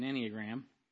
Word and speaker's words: Enneagram: So let me just start off with, you know, what is an Enneagram? E-N-N-E-A, but it's pronounced like Annie. Enneagram.